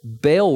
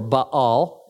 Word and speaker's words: Baal: Baal worship, or Baal is a way to pronounce it.